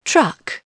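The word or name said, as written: truck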